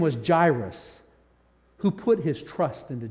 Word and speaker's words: Jairus: Jairus. Can you say Jairus? Kids, can you say Jairus? Jairus. Adults, can you say Jairus? Yeah, Jairus. J-A-I-R-U-S is the way it's spelled in the English version. So we'll pronounce it that way.